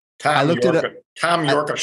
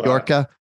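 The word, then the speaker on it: Yorke: Tom Yorka Shock will Yorka. I looked it up and it's pronounced York. – Oh, it's pronounced York. – Like New York. Yeah. – So it's a silent E.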